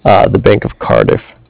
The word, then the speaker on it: Cardiff: It's C-A-R, like car, and then D-I-F-F, diff, like different, Cardiff. – Okay, the Bank of Cardiff? – Yeah.